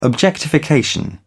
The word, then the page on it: objectification: British English